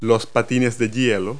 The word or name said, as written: skates